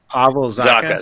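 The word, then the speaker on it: Zacha: I can't remember how to pronounce his name. – Pavel Zucka. – There we go. – Oh, Zucka. Yeah. That's right.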